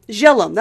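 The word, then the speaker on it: Zhellium: Okay, this was hard to pronounce. We were going to listen to her verification again. It's like... Zellum? Zellum? Zellum. That was it. Zellum. Zellum. With a Z-H-E-L-L-I-U-M.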